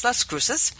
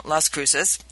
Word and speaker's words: Las Cruces: You're mispronouncing Las Cruces. – It's Las Cruces. Anyway, my ex-husband is from Las Cruces.